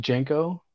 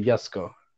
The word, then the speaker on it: Jesko: Anyways, it's Jesko, but it's actually Yesko. Is how Christian von Koenigsegg actually pronounced it.